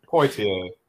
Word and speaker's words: Poitier: Poitier.